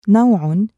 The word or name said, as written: نَوْعٌ